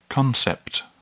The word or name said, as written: concept